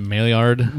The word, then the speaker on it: Maillard: Maillard.